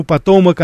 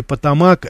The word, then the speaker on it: Потомак: Почему потомок, а не потомак? Э, потомак по-русски мы говорим потомок, это считается вот все-таки по, так сказать, по-английски, так сказать, ударение.